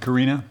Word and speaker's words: Karina: Did I pronounce it Karina? – Karina, yes.